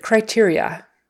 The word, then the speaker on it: criteria: Next word is exit criteria. Exit. E eh, as in red, I as in silver, exit, exit criteria, cry, I as in white, teria, long E, teary on both of those, Iria.